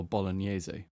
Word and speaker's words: bolognese: Or bolognese. Mm-hmm. Which is how the Italians pronounce it.